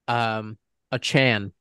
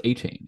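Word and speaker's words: Achane: Like, they are, like, a Chan. That's how you say it. The guy tweeted himself. Devin Achan. – I thought it was A-Chain. Is it not? – Yeah, A-Chain? – Everyone was saying A-Chain.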